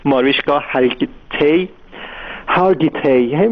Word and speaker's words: ماریشکا هارگیتی: خب خیلی اون کسی که براش توی مجله تایم مطلب رو نوشته ماریشکا اسمش رو نمیتونم خوب تلفظ کنم، اسم بگه این هنرپیشه معروف آمریکایی ماریشکا هارگیتی اسمش اینجوری تلفظ میشه، این برای تیلر سویفت توی مجله تایم نوشته که گفته که خیلی مستعد پدیده موسیقی پاپ.